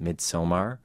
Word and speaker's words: Midsommar: You have a movie that's out now called Midsommar.